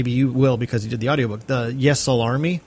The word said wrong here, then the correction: Ysalamiri: Um, mm, yeah, Ysalamiri. – Ysalamiri, right?